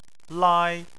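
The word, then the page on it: lai: tone 1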